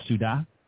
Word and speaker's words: Ciudad: Is that how it's pronounced, Ciudad? – Yeah, you did a great job.